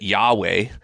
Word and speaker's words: Yahweh: It's YHWH. And so we pronounce it Yahweh. But it's not, that's not really what it is. And so try to say YHWH without, it's just breath.